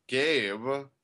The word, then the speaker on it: Gabe: And we're here with Gabe. Emphasis on the gay.